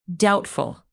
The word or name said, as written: doubtful